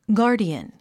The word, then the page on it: guardian: ɡάː r diən; ガーディアン